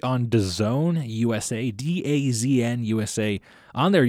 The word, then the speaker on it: DAZN: Yes, it's pronounced DAZN.